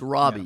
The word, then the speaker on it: Robbie: It's Robbie. Yeah. You keep saying Robbie. Robbie. – I pronounce it the way she does.